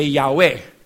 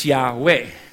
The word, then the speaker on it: Yahweh: We put A and E there from A, vowel of Adonai, and the vowel of Elohim. And that's why it's Yahweh.